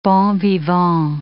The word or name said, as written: bons vivants